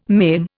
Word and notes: maim: Indian

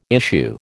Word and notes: issue: American English

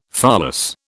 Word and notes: pholas: British English

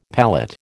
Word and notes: palette: American English

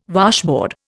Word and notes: washboard: Indian

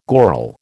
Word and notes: goral: American English

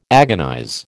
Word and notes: agonize: American English

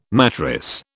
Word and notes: mattress: British English

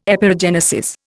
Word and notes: Epeirogenesis: Indian

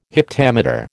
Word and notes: heptameter: American English

